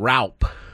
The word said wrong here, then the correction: Roupp: It's actually Roop. R-O-O-P is the way they pronounce it on baseball reference. So I've been calling him Raup because it looks like that. R-O-U-P-P, but it's Roop. R-O-O-P pronunciation.